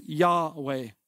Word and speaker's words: Yahweh: I'll pronounce that today as Yahweh.